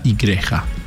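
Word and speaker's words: Igreja: Bueno, ahí puedes ir directamente también al canal oficial, JTA Oficial, JTA Church, uh-huh, y JTA Icreja. – Exactamente. – Si lo digo bien, perdón. – Sí, muy bien.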